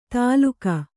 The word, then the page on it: ತಾಲುಕ: Kannada